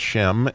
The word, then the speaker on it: Shem: Let's just think about the word name, which is Shem in the word in Hebrew. It's pronounced Shem, and just like the name of Noah's son, Shem is actually the word name.